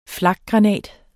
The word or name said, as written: flakgranat